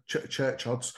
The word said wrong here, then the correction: Kershods: No, Kershods. – I'll take your word for it. Okay. – As in K-E-R. Think of it K-E-R, Kershods.